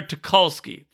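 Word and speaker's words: Tucholsky: I encourage just type in the search for YouTube, sportsmanship with Central Washington, Western Oregon, or Sarah Tuglowski. I don't want to butcher her last name. I want to make sure I get it right. Sarah Tuchulski, thank you.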